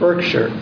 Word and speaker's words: Berkshire: And if you go to a town in Britain, which is spelled like this, how is that pronounced? – Berkshire. – How do you say this? – Berkshire. – How do you say this? – Berkshire. – It's actually Berkshire. It's Berkshire, right?